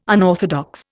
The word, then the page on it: unorthodox: Indian